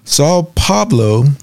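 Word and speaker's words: São Paulo: Sao Paulo, I'm not sure if we pronounced that.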